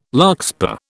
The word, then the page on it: larkspur: British English